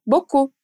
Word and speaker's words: beaucoup: É, bora reparar o som de beaucoup. Quantas letras, sílabas você escuta quando eu digo essa palavra? 4, certo? B-O-C-U, Boku. E se eu te disser que, na verdade, ela tem oito? B, E, A, U, C, O, U, P, Boku.